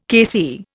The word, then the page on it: KC: Indian